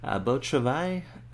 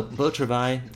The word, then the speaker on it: Beau Travail: Beau Travail? – That's fine. Yeah. Yeah, I mean, okay. The only difference is you would need to pronounce the R a little bit like a...